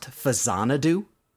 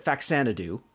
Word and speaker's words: Faxanadu: My understanding is that the title of that game is actually pronounced Fazanadu because it's in the Xanadu series of games.